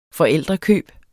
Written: forældrekøb

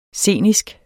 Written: scenisk

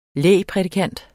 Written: lægprædikant

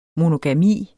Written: MONOGAMI

